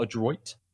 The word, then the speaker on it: Adroit: A-D-R-O-I-T. Adroit or Adroit? – Adroit. – I guess it depends on if they're pronouncing the French way.